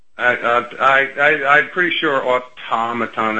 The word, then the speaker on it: automaton: I say automaton. I put the emphasis on the second syllable.